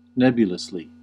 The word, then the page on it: nebulously: American English; /ˈnɛb.jʊ.ləs.li/